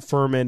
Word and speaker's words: Furman: They also selected, as I mentioned, Furham. Is it Furham? Have we gotten an official pronunciation? – Is it Furman? – Furham, Furman, Furman? – I think it's Furman. Furman. F-U-R-M-A-N.